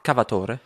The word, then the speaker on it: Cavatore: Also sagt man auch Cavatore? – Nee, man sagt Cavator, also man spricht französisch aus. – Ah, okay. – Also italienischer Name, der französisch ausgesprochen wird.